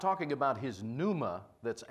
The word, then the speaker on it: pneuma: And it's the Greek word pneuma, and it's spelled P-N-E-U-M-A, but the P is silent, pneuma. It always refers to spirit.